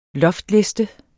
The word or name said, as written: loftliste